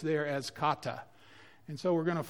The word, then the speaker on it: kata: And it's spelled K-A-T-A, kata.